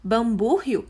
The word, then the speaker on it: Bambúrrio: Bambúrrio é uma palavra acentuada, proparoxítona, e por isso, na sílaba tônica bu há necessariamente o acento gráfico, agudo.